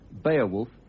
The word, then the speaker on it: Beowulf: Ladies and gentlemen, the classic work of Anglo-Saxon literature, Beowulf, or as the Anglo-Saxons would have pronounced it, Beowulf,